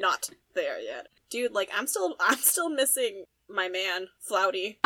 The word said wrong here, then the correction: Flutie: I thought you were gonna say Angel, but no, it was Flutie. – Oh, Flutie, excuse me. I can never say his name right.